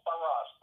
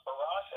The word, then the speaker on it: Barrosse: Paul Barrosse. Barrosse as in lacrosse.